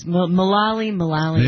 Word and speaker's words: Mulally: Mulally.